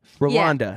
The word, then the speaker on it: Rwanda: Okay, so you actually just say it how it's spelled. Rwanda.